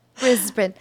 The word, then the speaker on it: Brisbane: Do you like how I pronounced it correctly? – You did. Brisbane. – And then Cairns. It's square, though, because it's spelled K-A-I-R-N-S, but it's Cairns.